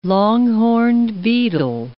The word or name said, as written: long-horned beetle